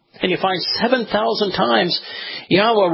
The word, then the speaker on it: Yahweh: And you examine the other words that are so common in Hebrew, like Torah, and it becomes obvious how to pronounce his name. It's Yahweh.